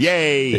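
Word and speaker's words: Ye: Every time that clip of old clip of Scott comes up, so we pronounce his name right now. Yes. It's, yay.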